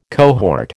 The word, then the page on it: cohort: American English